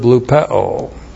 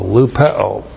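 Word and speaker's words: lupeo (λυπέω): And now we have the verb, not the noun lupe, but the verb lupeo. L-U-P-E long O, lupeo.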